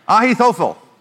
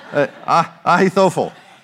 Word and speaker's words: Ahithophel: I'm going to say it like a Texas trying to be a Hebrew speaker says it. So Ahithophel, okay?